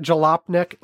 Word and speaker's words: Jalopnik: Is that how you say it? Jalopnik? Like, Jalopnik. Which makes sense because it comes off jalopy.